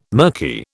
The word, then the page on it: murky: British English